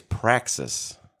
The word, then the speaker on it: praxis: Our word of the day is praxis. – Ooh, I like that. – Praxis. – I'm glad you didn't. I'm glad you didn't go French this time. – Praxis is the word. It's spelled P-R-A-X-I-S.